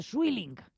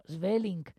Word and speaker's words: Sweelinck: Bueno, este Sweelinck, por cierto, que se escribe Sweelinck con W y dos Es, pero no se dice Sweelinck, como en algunos países de habla inglesa dicen, o en cualquier sitio, o aquí mismo. No, Sweelinck, porque el holandés es muy suyo.